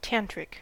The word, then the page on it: tantric: American English